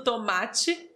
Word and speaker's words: tomate: porém se escreve com E. T-E, tomate. Aqui na nossa região nós falamos assim, ti, ti. Mas existem regiões que falam tomate, tomate.